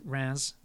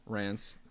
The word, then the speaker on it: Rennes: Yeah, Rennes. – Rennes.